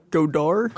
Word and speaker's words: Godard: Like, Godard? – Godard Day?